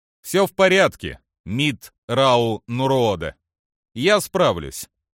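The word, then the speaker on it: Митт'рау'нуруодо: «Мит Рау Нуруода», — поправил его Траун. – «Я так и сказал. Мит Рау Нуруода». – «Произносится Мит Рау Нуруода». – «Да, Мит Рау Нуруода».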